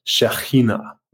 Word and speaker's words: Shekhinah: And if you say it in Hebrew, pardon my pronunciation, but it's something along the lines of Shekhinah, which is like the divine presence with us.